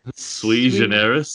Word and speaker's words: sui generis: Unencumbered by the prejudice that the EU is, I don't know how to pronounce that French bit. – Sui generis. – Sui generis.